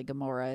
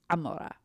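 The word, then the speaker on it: Amora (עמורה): Just like Gomorrah of Sodom and Gomorrah isn't actually Gomorrah, it's Amora.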